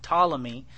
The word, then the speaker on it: Ptolemy: And then the Ptolemy, spelled with a P, Ptolemy, is the Egyptian Empire.